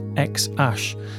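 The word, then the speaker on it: X Æ A-Xii: apparently pronounced X-Ash.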